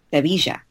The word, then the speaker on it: Sevilla: Sevilla.